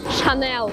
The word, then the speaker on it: Chanel: Так вот, в Америке Chanel произносится с твердым L. Chanel.